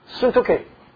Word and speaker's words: Syntyche: And I beseech Euodia and beseech Syntyche. You pronounce it differently. But the Greek pronounce as syntyche.